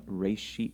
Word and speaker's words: Reshit: The Reshit.